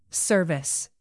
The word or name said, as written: service